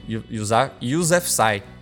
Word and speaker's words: Yousafzai: E eu pesquisei a pronúncia. Malala Yousafzai mesmo. Yousafzai.